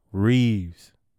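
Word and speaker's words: Reaves: Yes, y'all heard me say it right. Austin Reeves.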